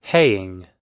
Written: haying

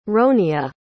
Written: ronia

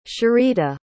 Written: sherita

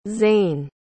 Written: Zaine

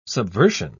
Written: subversion

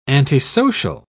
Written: antisocial

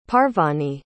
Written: parvani

